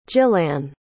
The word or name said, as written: jillanne